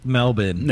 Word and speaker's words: Melbourne: Melbourne.